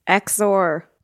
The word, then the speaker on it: XOR: XOR.